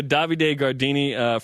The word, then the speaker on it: Davide Gardini: We're talking to Davide Gardini, from Italy, freshman volleyball player.